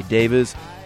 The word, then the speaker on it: Davies: It was written by Ray Davies, and yes, that is the way he pronounces his name.